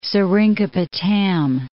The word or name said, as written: Seringapatam